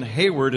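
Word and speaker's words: Heyward: So, for example, Jason Hayward is H-A-Y. I knew it's spelled H-E-Y.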